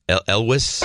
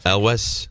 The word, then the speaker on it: Elwes: Well, Carrie, I think it's pronounced Elwes. – I think you're right. Well, you had it right the first time. – Elwes.